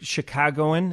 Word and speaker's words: Chicagoan: And at that time, a great Chicagoan, is that how you say it? – Yes.